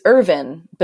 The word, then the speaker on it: Irvine: it's Irvin, but it's spelled like Irvine, the city in California.